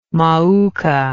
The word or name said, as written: mauka